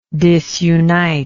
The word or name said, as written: disuniter